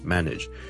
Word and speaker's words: manage: 你 Ladies and gentlemen, boys and girls, 今天想跟大家讲 manage 这个字的发音 manage M-A-N-A-G-E manage，由于这个字后面 N-A-G-E nage 呢是串做 N-A-G-E，很多人就会将它读成 manage 的，但是事实上呢我这个字是读 manage nage nage nage，你当是好像 N-I-D-G-E 那样读。 Okay, manage.